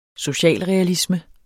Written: socialrealisme

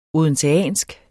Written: odenseansk

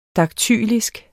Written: daktylisk

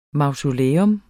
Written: MAUSOLEERNES

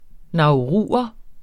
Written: nauruer

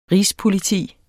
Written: rigspoliti

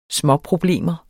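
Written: småproblemer

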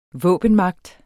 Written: våbenmagt

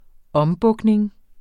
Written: ombukning

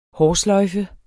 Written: hårsløjfe

